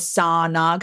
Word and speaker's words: Sarna: Americans and Canadians really pronounce R's. So that's why I have it as Sarna. Because the R's pronounced, just not Americanly.